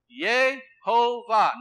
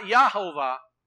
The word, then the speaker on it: Yehovah: Yehovah. Not Yahovah, but Yeh.